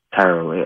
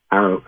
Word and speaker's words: tarot: We use the French tarot with the T at the end. It's usually silent when pronounced because of the influence of the French occultists in the English-speaking world.